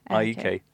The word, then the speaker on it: AIK: Well, how do I pronounce that? – Yeah. – AIK. Yeah, that's okay. good.